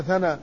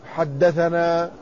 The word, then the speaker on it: ثنا: فمثلا علماء الحديث إذا كتبوا يكتبون السند، فإذا كتبوا حدثنا يختصرونها بأن يحذفوا الحاء والدال ويتركوا لفظ ثنا، لكن لا ينطقونها ثنا، ومن الخطأ البين ما يحدث لدى كثير من الطلاب أنه إذا قرأ يقول ثنا فلان ثنا فلان، وهذا خطأ، فإن كلمة ثنا رسمت هكذا لكنها لا تكتب لا تنطق إلا حدثنا، وكذلك كلمة ثني تنطق حدثني، وكلمة أنا المراد بها أخبرنا.